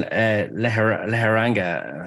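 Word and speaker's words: Lejarraga: He beat Courtney Pennington recently, but a big win in against European champion in Bilbao, Kerman Leheranga, I think is how you pronounce the second name.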